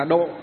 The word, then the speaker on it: độ: Nhưng mà Phật giáo chúng ta đọc chạy đi, phát âm chạy đi là độ.